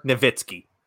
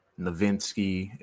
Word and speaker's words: Nowitzki: Granted, how many sportscasters did we see that could not say Dirk Nowitzki for 20 years? – Nowinski.